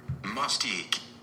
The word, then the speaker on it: Mustique: Mustique. – Mustique. It's more like moss. – Mustique. – Like moss, like M-O-S-S.